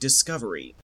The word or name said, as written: discovery